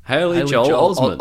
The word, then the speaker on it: Haley Joel Osment: Haley Joel Osment.